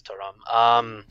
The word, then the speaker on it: Thuram: Is this pronounced Turam, and which Turam is this? – This is Marcus Thuram.